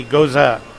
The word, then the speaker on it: Gose: I'm drinking a, is it pronounced Goes or Goza? Does anybody know? How do you pronounce it? G-O-S-E, Goza. That's very good.